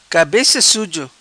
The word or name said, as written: Cabeca Suzo